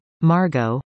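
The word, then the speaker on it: Margot: Margo, which several people instantly mispronounced by adding the silent T.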